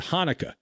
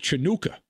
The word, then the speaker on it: Hanukkah: Guy's an all time legend, Smokey Robinson, who did not know how to pronounce the word Hanukkah. He called it Chanukah.